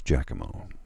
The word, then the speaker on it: Giacomo: Giacomo would be how it is spelt, but as I understand, it's pronounced Giacomo.